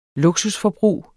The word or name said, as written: luksusforbrug